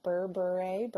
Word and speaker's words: Berbere: Berbere, Berber.